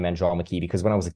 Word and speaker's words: Andromache: Andromache because if you look at the way it's it's a hard name to spell phonetically. You go, is that Andromatch? Is it Andromachi?